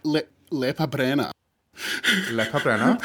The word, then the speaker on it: Lepa Brena: Lepa Brena, who I've learned an awful lot more about through Adam's social media.